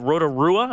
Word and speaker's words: Rotorua: She won a 100-mile race in, sorry, is it Rotorua? Am I saying that right? – Yep.